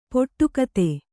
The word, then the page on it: ಪಿಡಿಕುತ್ತು: piḍi kuttu